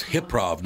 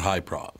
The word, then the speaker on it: Hyprov: Oh, so it's pronounced Hip-Prov, not High-Prov.